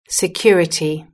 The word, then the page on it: security: British English